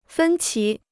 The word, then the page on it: 分歧: fēn qí